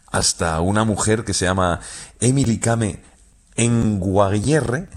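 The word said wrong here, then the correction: Kngwarreye: lo he dicho mal. – Emily Kame. – Con Guarreye. – Emily Kame con Guarreye, sí.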